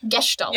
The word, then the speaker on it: Gestalt: Gestalt. – Yeah, I was like, I want to say the S is like a sh.